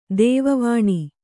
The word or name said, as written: ದೈವವಾಣಿ